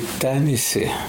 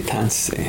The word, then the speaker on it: tanisi: For example, when I say tansi, our people say tanisi, tanisi. They don't say tansi.